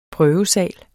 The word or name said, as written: prøvesal